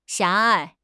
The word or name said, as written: 狭隘